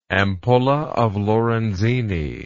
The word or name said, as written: ampulla of Lorenzini